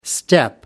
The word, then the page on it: step-up: American English